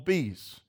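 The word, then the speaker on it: Bea's: Somebody took me to a restaurant called Bee's. It's spelled B-E-A-apostrophe-S.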